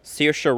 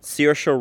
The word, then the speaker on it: Saoirse: and then somebody named Saoirse Ronan. But I'll Saoirse Ronan. – Saoirse. – Oh my dill! Stop! Saoirse Ronan.